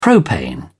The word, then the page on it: propane: British English